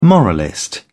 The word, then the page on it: moralist: British English